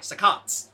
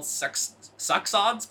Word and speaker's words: saccades: S-A-C-C-A-D-E-S. – Saccades. Saccades. – One would think it's called succsods, because that's like how usually two C's are said in science, but I looked it up and it's saccades.